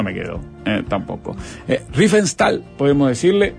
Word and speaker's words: Riefenstahl: Si Leo le vas a decir Riefenstahl. – Yo le digo Leni Riefenstahl. – Leni Riefenstahl. Lo escuchamos a Leo. Como, acá es como Leo le diga en la pronunciación.